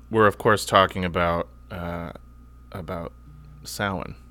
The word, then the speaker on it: Samhain: Ooh, pronounced correctly as well. – Yeah. Unless I'm talking about the Glenn Danzig band, because I think they call that Sam Hain. I do believe they call it Sam Hain.